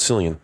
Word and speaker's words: Cillian: I go with Cillian.